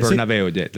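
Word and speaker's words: Bernabéu: Bernabeu, that's right.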